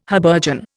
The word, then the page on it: habergeon: Indian